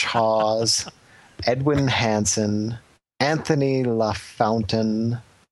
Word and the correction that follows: LaFountain: not even LaFontaine, LaFountain.